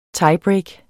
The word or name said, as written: Tiebreak